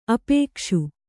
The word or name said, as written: ಆಪೇಕ್ಷೆ